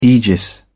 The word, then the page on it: aegis: British English